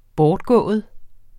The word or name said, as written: bortgået